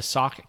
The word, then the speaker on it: Sauk: He is the executive director of the Sauk County Development Corporation in Wisconsin.